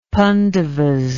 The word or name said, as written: pandavas